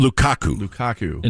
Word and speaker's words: Lukaku: What about the decision on Belgium's side not to start Doku and Lukaku? Is that how you say it? – Lukaku.